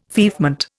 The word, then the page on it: feoffment: Indian